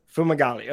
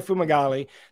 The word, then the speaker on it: Fumagalli: Fumagalli. Okay. Cristiano Fumagalli, Chief Technology Officer and co-founder of Mago.